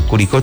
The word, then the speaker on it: Curicó: Saludito a la distancia para Carla Enríquez desde el sur de Chile, dice que es de Curicó, así se pronuncia, Curicó, Chile.